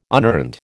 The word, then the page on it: unearned: American English